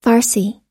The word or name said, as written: farsi